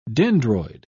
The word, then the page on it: dendroid: British English